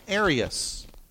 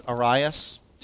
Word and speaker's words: Arius: So later on, we get these guys coming up like, and I'm going to move back over to the far left, Arius. Or I've heard some people pronounce it Arius. Take your pick.